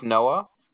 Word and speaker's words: Noah: My name's Noah. How can I help you? – I'm sorry. What did you say your name was? – My name's Noah.